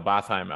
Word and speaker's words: Botheim: Bohainen, who I mentioned, I mispronounced his name before. He is injured, the player from Bodo Glimt. So Roma won't have to. Botheim.